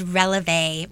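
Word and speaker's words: Relevé: It's called Releve, Releve Studios, and Releve is a French term.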